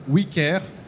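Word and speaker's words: OuiCare: qui est écrit O-U-I pour maintenir cet aspect français, mais en même temps qui se prononce « oui » comme le « oui » nous, anglais, le « we care », parce que notre métier, c'est de porter attention.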